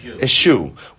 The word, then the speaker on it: eschew: What is the word? Eschew? Eschew? Eschew. Eschew. How do you pronounce it? Eschew.